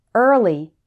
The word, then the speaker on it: early: It's pronounced er like in her in the words early, earn, learn, earth.